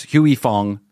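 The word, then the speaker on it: Huy Fong: Is that how you say it? Huey Foods? Huey Fong sriracha? – Yes.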